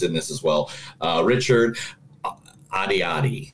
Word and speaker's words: Ayoade: Because right now in my head, it is saying Richard Ayoade. – I'm seeing, okay, that's what you're seeing in your head, but that's what I'm reading. – That's what it says. Okay. That's how it's pronounced.